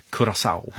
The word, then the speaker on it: Curaçao: Jag har fått stöd av producenten att man ska tydligen uttala det Curaçao.